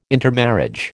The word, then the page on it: intermarriage: American English